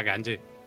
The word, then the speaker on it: Gungi: Se escribe Gungi, pero, pero se pronuncia Ganji.